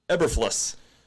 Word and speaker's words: Eberflus: I had to Google this guy because I couldn't remember how to say his name. It is Matt Eberflus.